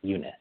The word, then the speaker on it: Junes: But his name is Yunus Zahdi. That's how you pronounce it, Yunus.